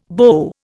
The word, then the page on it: BO: Indian